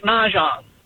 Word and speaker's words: Mahjong: The second time you said it right, Mahjong.